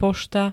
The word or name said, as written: pošta